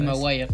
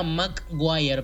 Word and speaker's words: Maguire: Toby McGuire. Maguire.